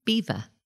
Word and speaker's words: Belvoir: And the English, as we know, can't pronounce French. And so over generations, it has become Beaver. – Beaver from Belvoir.